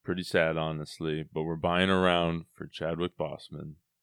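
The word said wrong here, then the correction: Boseman: Bozeman. Bozeman. I always say it wrong.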